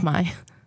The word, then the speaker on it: Khmer: Khmer. – Khmer. It's pronounced my. – Khmer. – My, the K is silent. Oh. I know, it's weird. It's confusing, but it's my.